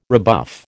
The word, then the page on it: rebuff: American English